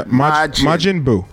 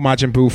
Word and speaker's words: Majin Buu: Majin Buu. Yeah, Majin Buu. – Majin. – Majin. – M-A-J-I-N. – Correct. And Buu is spelled B-U-U.